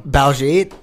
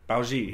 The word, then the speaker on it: Baljeet: It rhymes with Khajiit from Skyrim, you know? – Baljeet? – Yeah, what the fuck is that?